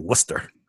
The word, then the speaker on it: Worcester: Worcester has like 14, 15 letters in the name when you spell it out, but it's like Worcester. – Two yeah. – syllables at most. One and a half, maybe.